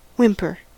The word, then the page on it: whimper: American English